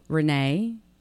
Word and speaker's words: Renee: Renee, R-E-N-E-E, Sacharny, S-A-C-H-A-R-N-Y.